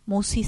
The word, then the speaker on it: Musci: El canal lleva mi nombre, Marisa Musi. Musi se escribe M-U-S-C-I latina.